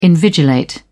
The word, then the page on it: invigilate: British English